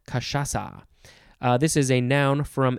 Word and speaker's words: cachaça: I think that's how it's pronounced. Cachaça. It is spelled C-A-C-H-A-C-A, but the last C has the little sort of question mark S-shaped thing at the bottom of it, which in this case is Brazilian Portuguese.